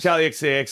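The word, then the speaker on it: Charli XCX: Charlie XCX.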